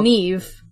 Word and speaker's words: Niamh: You said her name wrong. Can we start over? – Is it Niamh? – It's okay. Neve. – Neve. – Oh, Neve. Okay.